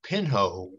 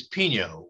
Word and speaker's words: Pinho: I'd like to make just one very collateral comment, and that is to thank counsel for, I think, correctly pronouncing the case name Pinho, when both of my colleagues, whom I love dearly, have pronounced it as Pino, which shows where their minds happen to be in this case and where counsel most appropriately happened to be thinking.